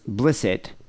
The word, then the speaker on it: Blissett: He goes, "Hey, weirdos, just so you know, Luther Blissett, pronounced Blissett, this is from when we did that QAnon show a few weeks ago.